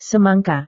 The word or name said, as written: semangka